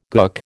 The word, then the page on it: gook: American English